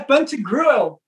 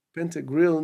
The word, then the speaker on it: Pantagruel: Como é que é? É Pantagruel. Pantagruel é Nativity. É, é, é francês.